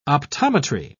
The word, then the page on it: optometry: British English